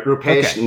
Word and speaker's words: Roopesh: Almost there. Roopesh Nair is how you say Nair.